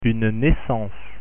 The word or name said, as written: naissance